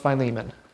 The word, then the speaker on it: Philemon: Well, don't pronounce it differently, because it's pronounced Philemon. Philemon. How did you pronounce it? – Philemon. – No, it's Philemon.